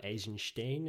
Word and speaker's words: Eisenstein: I know it was directed by Sergei Eisenstein, or Eisenstein, if I'm trying to be a little bit more authentic to the Russian pronunciation.